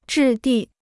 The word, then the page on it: 质地: zhì dì